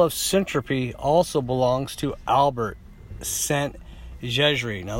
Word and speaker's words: Szent-Györgyi: Albert Szent-Györgyi, a brilliant Hungarian scientist who won two Nobel Prizes in 1937 and 1955 for his research.